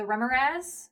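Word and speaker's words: Remorhaz: Still in the monster manual is the, I never know how to say this, Zach knows, the Remoraz? – Yeah, that's it.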